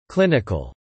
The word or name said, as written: clinical